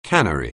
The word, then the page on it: cannery: anglais